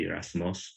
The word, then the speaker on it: Erasmus: Erasmus, to be actively pursuing some form of justice.